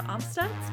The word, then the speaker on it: Amstutz: And you can find me on all social media platforms at Sandra Amstutz.